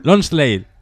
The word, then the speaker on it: Lonsdale: La camiseta está inglesa que pone London, que creo que se pronuncia sí. – Lonsdale. – Sí, Lonsdale.